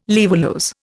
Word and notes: laevulose: Indian

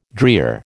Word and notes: drear: American English

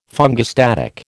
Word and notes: fungistatic: American English

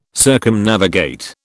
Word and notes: Circumnavigate: British English

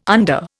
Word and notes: under: Indian

